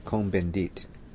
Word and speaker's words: Cohn-Bendit: Daniel Cohn-Bendit. Cohn-Bendit.